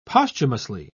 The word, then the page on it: posthumously: British English